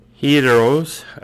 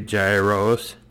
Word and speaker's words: gyros: While some sandwich rivals have managed impressive growth through innovation, such as Arby's adding gyros, I've heard that's pronounced that way, some say gyros, to the menu, Subway sandwich lineup hasn't seen any showy changes as sales have slumped in recent years.